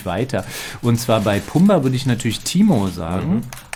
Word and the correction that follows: Timon: Ja, und oh, Timon, ja, das war falsch aus, war so ein bisschen ja, slangmäßig ausgesprochen.